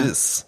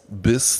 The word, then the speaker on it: Biz: Bis. Wie schreibt man sich? B-I-Z? – B-I-Z. Ah, ja. Bis. Bis Stone. Ja? – Ne?